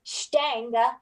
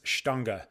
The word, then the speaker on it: Stange: What got, and bad American public school system student, Steng, is how I would pronounce it. How do we pronounce it? – So I heard this from Master Cicerone Rich Higgins, who is fluent in German and has traveled to Germany many, many, many, many times. And I've heard him pronounce this Stange.